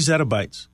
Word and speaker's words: zettabytes: The global annual IP traffic is going to exceed 3 zettabytes. Am I saying that right? – 3 zettabytes.